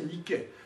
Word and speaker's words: Nike: On prononce Nike, mais on devrait prononcer Nike.